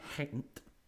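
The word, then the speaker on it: Gent: And the winger Osman Bakari from Hent, because you meant to pronounce G like a H.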